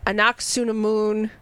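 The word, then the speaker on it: Anck-su-namun: You know, Anaximoon. Did I say it right that time? – Yep.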